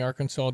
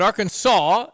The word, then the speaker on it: Arkansas: It's a battle of the silent S. Illinois, Arkansas, Des Moines. Come on. – Oh. – Illinois, Arkansas. So there's a little S here, but not really at the end.